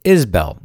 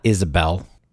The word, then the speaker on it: Isbell: And he was also a second cousin of Levi Isabel, born in 1770, the fifth great grandfather of singer songwriter Jason Isabel. And let it be known that halfway through telling you that, I realized I was pronouncing Isbell incorrectly. Isbell, not Isabel.